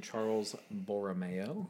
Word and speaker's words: Charles Borromeo: So today is the feast of St. Charles Borromeo. – Yes. – The correct pronunciation.